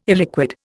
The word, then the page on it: illiquid: Indian